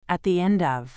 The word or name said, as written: at the end of